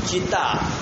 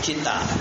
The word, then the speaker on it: chitta: Chitta. Chitta means that cremation fire when the body is burnt. That is called chitta. – How do you spell that? – C-H-I-T for Tom. T-A. Chitta. Chitta.